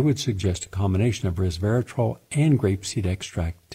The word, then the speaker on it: resveratrol: The compound, you've heard me mention many times, resveratrol, R-E-S-V-E-R-A-T-R-O-L, three syllables, which are found in grape skins and grape seeds.